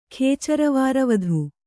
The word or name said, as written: ಖೇಚರವಾರವಧು